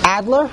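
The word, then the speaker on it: Ajdler: A-J-D-L-E-R. I don't know how to pronounce that. – I think the J is silent, like Adler. – Adler? – I think the J is silent. – Adler.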